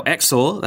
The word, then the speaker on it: Xor: Now Xor, that's spelled X-O-R, a company founded by Virtu's former design chief and staffed by many former employees, is trying to recapture its former spirit.